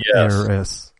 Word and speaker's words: heiress: Like is it heir? Harris? Like an heir to the throne? So it's an heiress. – Heiress. So the H is silent.